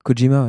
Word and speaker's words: Kojima: Kojima, ouais. – Ouais, tu sais qu'il n'y a pas de D, c'est juste K-O-J-I-M-A. Ouais, c'est comme ça, ouais, écoute, c'est comme ça que ça se prononce, mon cher ami.